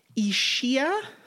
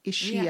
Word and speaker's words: Ischia: It's Ischia. – Okay. – I-S-C-H-I-A. Ischia.